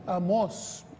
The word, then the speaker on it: Amós: No, Amós, tiene un acento en la O.